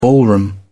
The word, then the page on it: ballroom: British English